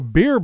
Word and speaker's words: Bere: By the way, you may remember the Viking-style beer barley Brooke Laddie distilled several years ago. That whiskey will be released soon as Brooke Laddie Beer, that's spelled B-E-R-E.